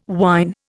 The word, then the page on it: wine: Indian